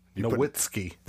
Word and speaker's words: Nowitzki: You said his name wrong. – I've been waiting to say that. – You said Nowitzki. – Nowitzki. – There you go. – He's German.